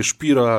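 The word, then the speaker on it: Spiró: Po prostu tak się pisze jego nazwisko, czyta się Szpiro,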